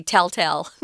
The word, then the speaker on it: telltale: This word telltale is hard to say because the vowels are actually different tell and then tail. But Americans are really lazy, so we just say telltale. mm. We make the same uh, vowel sound for both those words if we are just speaking, you know, casually.